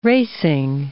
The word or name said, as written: racing